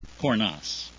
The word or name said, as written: cornas